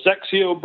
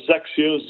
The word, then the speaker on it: XXIO: The basics of the brand are the Sexio brand, pronounced Sexio. You pronounce it like it was Z-E-K-S-I-O. – Sexio. But it's spelled, okay. – Yep. Yeah, Sexio, but it's spelled X-X-I-O.